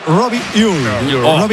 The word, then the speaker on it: Robbie Ure: A ver, el club pasó incluso un audio, lo ha pasado. Y en concreto sería Robbie Ure. – Qué bien lo pronuncia.